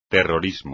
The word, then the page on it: terrorismo: espagnol